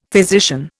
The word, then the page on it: physician: Indian